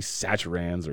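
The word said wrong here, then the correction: Zatarain's: or Satarans or whatever. – Zatarans. – Zatarans.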